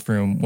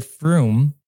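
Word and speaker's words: Frum: This version was backed up in the 1940s by the island administrator, Alexander Ratul, who said that the word Froome is actually pronounced like the word broom in Tannese.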